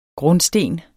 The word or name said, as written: grundsten